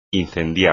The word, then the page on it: incendiar: espagnol